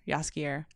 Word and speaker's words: Jaskier: Jaskier.